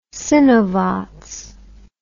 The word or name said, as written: Sinowatz